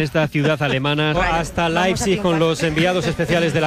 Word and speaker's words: Leipzig: Es Leipzig, es lo que nos dijeron que que teníamos que decir.